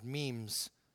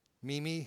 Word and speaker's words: meme: I've been collecting, and I guess I've been pronouncing it wrong now, but on the internet, you've got these M-E-M-E things, which apparently are called memes. Meme-y? I wanted to say meme-y, you know, and then we'd have to start warming up to saying.